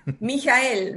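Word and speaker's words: Michael: Por cierto, ¿cómo pronunciamos a Ende? – Mijael, Mijael. – Mijael Ende? – Mijael. perfecto.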